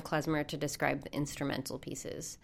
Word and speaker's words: Klezmer: Klezmer.